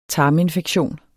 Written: tarminfektion

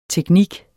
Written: teknik

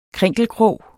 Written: krinkelkrog

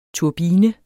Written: turbine

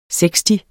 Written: seksti

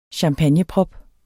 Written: champagneprop

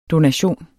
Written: DONATIONEN

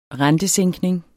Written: rentesænkning

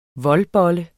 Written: voldbolle

